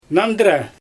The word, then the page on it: nandyra: Xyzyl